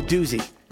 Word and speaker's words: doozy: It's going to be a doozy. Huh. Doozy rhymes with jacuzzi.